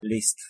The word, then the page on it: liszt: Hungarian